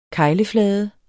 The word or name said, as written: kegleflade